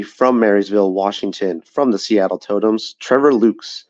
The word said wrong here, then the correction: Lukes: who had, or I keep, you know what, I keep saying his name wrong. I think it's Lux, actually.